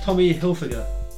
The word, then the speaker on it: Tommy Hilfiger: Tommy Hilfiger.